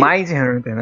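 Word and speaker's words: Mindhunter: tu passa por um Mid Hunter da vida, né? – Pois é. Aí. É aí... Mind Hunter, na verdade, é. eu falei errado.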